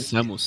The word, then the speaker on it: Samus: Quando o jogo saiu, é, o pessoal a é jogando Sam Metroid. Samus. – Samus. É Samus. – Samus. Eu nunca sei a pronúncia disso aí, mas acho que é Samus.